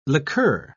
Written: liqueur

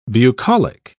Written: bucolic

